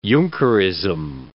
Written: Junkerism

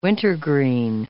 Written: wintergreen

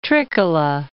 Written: Trikkala

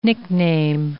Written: nickname

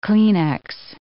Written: Kleenex

